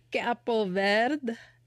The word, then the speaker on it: Cabo Verde: Cabo Verde is how they usually pronounce it on the news.